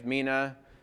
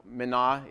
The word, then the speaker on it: mina: I should say that a talent in that particular case, as well as a mina, and I realize it's pronounced different ways, mina, mina is actually the Hebrew or the Greek rather way of pronouncing that.